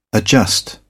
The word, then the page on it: adjust: British English